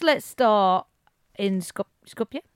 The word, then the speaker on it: Skopje: Welcome back from Skopje? How do you pronounce Skopje? – It? – Skopje? – Skopje? Skopje. Yeah.